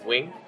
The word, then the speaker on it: Nguyen: And Nguyen is a most common whitewashed, version of our last name. – Okay. – But everyone pronounces as Wing after we correct that.